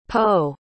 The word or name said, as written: po